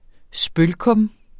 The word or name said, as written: spølkum